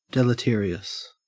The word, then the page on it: deleterious: Australian English